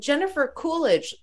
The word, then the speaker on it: Jennifer Coolidge: Yeah, and Jennifer Coolidge lives there too.